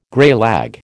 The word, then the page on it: greylag: American English